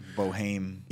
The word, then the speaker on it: Boeheim: Because you're looking at it, and it says it looks like Boeheim. – Yeah. – And it's Boeheim. Looks like Boeheim.